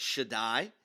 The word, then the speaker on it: Shaddai: If you put it together, you get Shaddai is what it looks like. We pronounce it Shaddai.